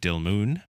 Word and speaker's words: Dilmun: Dilmun, maybe? Yeah, U as in tube.